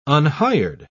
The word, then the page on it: unhired: British English